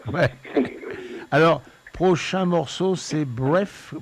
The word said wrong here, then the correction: Breathe: comment on prononce, Brief? – Alors, c'est Breathe, ouais.